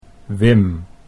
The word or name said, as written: Wim